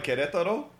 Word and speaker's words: Querétaro: I'll go with like Querétaro, which is close enough.